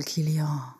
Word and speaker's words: Kylian: Wir haben Kylian Mbappé. Habe ich das richtig gesagt? – Er ist auf jeden Fall Kylian.